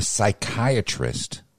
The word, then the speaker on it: psychiatrist: You know, why can't you hear a psychiatrist using the bathroom? – I don't know. – Because the pee is silent.